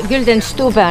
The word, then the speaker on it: Güldenstubbe: Ja Güldenstube või kuidas ta? – Güldenstube on hääldus. – Saksa keeles, jah.